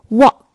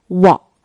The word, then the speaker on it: wok: 英语拼写跟粤拼一样，只是没有声调，可以读作 wok。跟粤语发音很大不同是，粤语最后这个 K 是不真正发出声音的，它是 wok。那用这种 wok 这种锅炒菜呢，最重要的是要讲究一个 wok hei，就是这个锅的气。